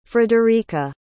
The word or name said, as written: frederica